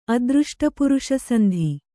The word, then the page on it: ಅದೃಷ್ಟಪುರುಷಸಂಧಿ: adřṣṭapuruṣasandhi; Kannada